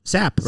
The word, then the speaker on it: Sappe: Sappe, right? – Sappe. Yeah. – S-A-P-P-E.